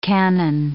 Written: cannon

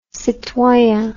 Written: citoyen

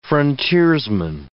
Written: frontiersman

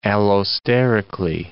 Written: allosterically

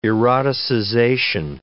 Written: eroticization

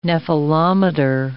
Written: nephelometer

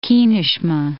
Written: Kineshma

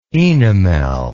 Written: oenomel

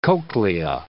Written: cochlea

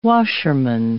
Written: washerman

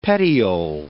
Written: petiole

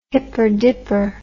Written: hipper dipper

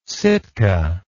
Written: Sitka

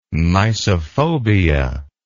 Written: mysophobia

